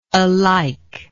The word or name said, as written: alikeness